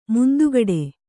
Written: ಮುಂದುಗಡೆ

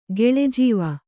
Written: ಗೆಳೆಜೀವ

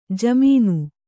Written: ಜಮೀನು

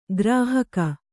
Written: ಗ್ರಾಹಕ

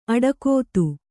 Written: ಅಡಕೊತ್ತು

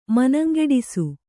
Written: ಮನಂಗೆಡಿಸು

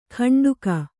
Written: ಕಂಡುಗ

